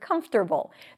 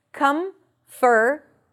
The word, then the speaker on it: comfortable: It is very uncomfortable to pronounce the word comfortable. There are two different pronunciations here. Either comfortable, and the T is flapped in American English.